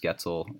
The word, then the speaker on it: Goetzel: I am Noah Getzel, and we are here with Becca Winkert. You can find her on Twitter at BeccaMVP. You can find me, Noah_GOETZEL. Last name's Getzel. It rhymes with pretzel.